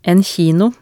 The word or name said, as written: en kino